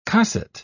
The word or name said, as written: cosset